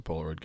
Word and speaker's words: Polaroid: No, did I say that right? Polaroid? – It's a Polaroid camera.